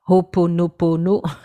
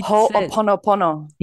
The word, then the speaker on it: Ho'oponopono: Ho'oponopono. – Well, it Ho'oponopono.